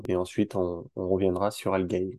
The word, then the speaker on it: Algae: Aujourd'hui, j'ai le plaisir d'accueillir Adrien, cofondateur de Algae. Je ne sais pas si je prononce bien. si, tu prononces bien.